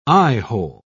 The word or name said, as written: eyehole